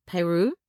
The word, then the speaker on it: Peyroux: Yes, I do, and I think she probably is Madeleine Peyroux. I hope I'm saying her name correctly. – Peyroux.